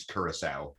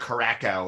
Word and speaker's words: Curaçao: It's Curaçao. – Curaçao. – Ugh, I hate that. – Yeah, I like it. I thought it was like Caracao or something.